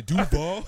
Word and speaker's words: Duval: No, you said it right. I would say Duval.